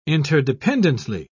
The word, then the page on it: interdependently: British English